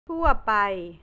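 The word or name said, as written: ทั่วไป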